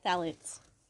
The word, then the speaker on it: Phthalates: Phthalates. Is that the word? – Yeah, sounds about right.